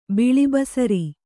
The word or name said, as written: ಬಿಳಿಬಸರಿ